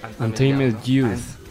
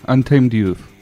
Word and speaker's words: Untamed Youth: Pues tuvo la suerte de conocer a Deke Dickerson de Untamed Youth. ¿Se dice así? – ¿Cómo? Untamed Youth. – Eso, Youth. – Profesor. – ¿De qué? – Untamed Youth. – Eso. Ahí está, perfecto.